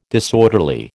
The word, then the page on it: disorderly: American English